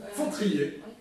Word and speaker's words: Fautrier: Et on dirait que c'est une queue de poisson ou des fautriers? – Fautriers, absolument.